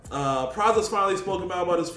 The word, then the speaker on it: Pras: But before we get to that, the Fugees, Praz, did I say his name right? – Praz, yeah.